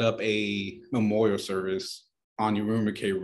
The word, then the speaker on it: Urumqi: Urumqi.